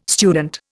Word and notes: student: Indian

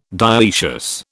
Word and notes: dioecious: British English

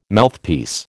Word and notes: Mouthpiece: American English

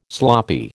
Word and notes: sloppy: American English

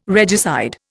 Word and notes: regicide: Indian

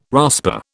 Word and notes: rasper: British English

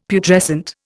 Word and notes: Putrescent: Indian

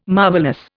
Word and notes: marvellous: Indian

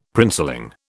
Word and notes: princeling: British English

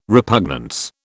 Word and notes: repugnance: British English

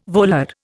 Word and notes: volar: Indian

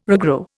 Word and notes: regrow: Indian

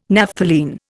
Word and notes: Naphthalene: Indian

